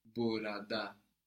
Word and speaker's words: burada: The word for here is burada. – Burada. – But in the spoken language, you'll often hear burada. Burada. So it's spelled burada, Burada, but it's often shortened in spoken to burada, burada.